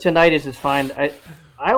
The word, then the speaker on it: tinnitus: Yeah, or tinnitus, I guess they pronounce it. – Is that how you're supposed to say it? I've been saying it wrong my entire life. – Yeah, tinnitus is what people who seem to be medically inclined.